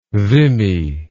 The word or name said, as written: vimy